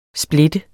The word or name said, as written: splitte